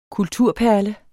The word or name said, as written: kulturperle